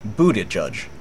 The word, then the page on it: Buttigieg: American English